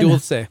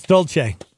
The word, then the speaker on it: Dolce: I'm pretty sure she pronounces it Dulce. – Nah, it's Dolce. – Okay. – Like Dolce Cabana.